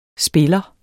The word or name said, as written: spiller